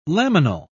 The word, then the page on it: laminal: British English